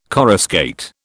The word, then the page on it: coruscate: British English